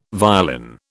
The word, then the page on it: violin: British English